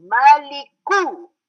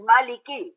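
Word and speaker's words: Maliku (مالك): Everybody pronounces Maliki because they have seen Malik in other parts. Maliki Yawm Middin in the first part. So here also they go Maliki. It's not Maliki here, it's Maliku Yawmiddin.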